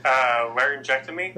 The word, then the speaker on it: laryngectomy: And you want to try and pronounce that surgery? What is that? – Laryngectomy. – There you go. I would have completely butchered that.